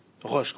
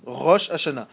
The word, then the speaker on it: Rosh Hashanah: Resh, Aleph, Shin. Il y a un Aleph. C'est une syllabe, le Aleph, c'est une syllabe qui ne se prononce pas, mais c'est une syllabe qui est écrite. Rosh Hashanah.